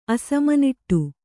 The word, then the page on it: ಅಸಮನಿಟ್ಟು: kannada; asamaniṭṭu